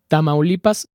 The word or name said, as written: Tamaulipas